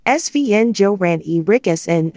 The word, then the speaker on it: Sven-Göran Eriksson: Sven-Göran Eriksson, Swedish pronunciation, Sven-Göran Eriksson, February 5, 1948, August 26, 2024, was a Swedish football player and manager.